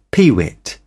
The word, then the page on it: peewit: British English